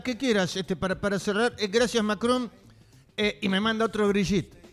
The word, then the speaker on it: Macron: ¿Se dice así, Macron? Sí.